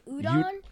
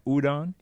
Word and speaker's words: udon: It's called, so it's udon. – I was going to say udon, but I figured that's not how you say it. It's called udon? – Yeah, udon noodles from Costco.